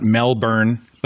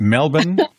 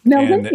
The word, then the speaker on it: Melbourne: You know, we got to see Sydney and not Melbourne, but Melbourne. Did I say it right? – Melbourne, And Brisbane, you know, but we didn't get a chance to go out and see any of the more exotic things.